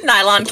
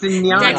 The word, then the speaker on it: Nyan: Nylon Cat. – It's Nyan.